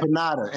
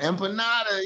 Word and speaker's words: empanada: How do you pronounce that? – Empanada. Empanada, yeah.